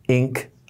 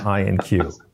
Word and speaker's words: Inq: Well, first of all, I appreciate that now I know how to pronounce Inc. IQ Consulting. It's not INQ. So if I could stamp that brand into everybody's mind, INQ is pronounced Inc.